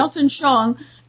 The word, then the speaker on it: Weltanschauung: and the idea of the Weltanschauung, I'm not even sure how to pronounce it. – Yeah, Weltanschauung.